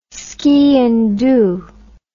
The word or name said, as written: sgian dhu